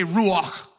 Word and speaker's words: ruach: Somebody say ruach. But say it like you have something stuck in your throat. Ruach. You got to say this like with a baritone sort of tenor.